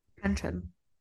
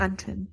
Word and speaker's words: Antrim: But closer to home for you now, Ballyboley Forest in Antrim, Northern Ireland. – Antrim. – How do you say it? – Antrim.